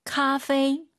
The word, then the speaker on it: 咖啡: 우리 청취자분들 중에서 우리 선배님들, 카페이 아니고 페이입니다. 카페이도 아니고 커피만 다 같이 발음하시죠. 하나, 둘, 셋. – 咖啡。 – 카페이, 페이 아니에요. 카페이.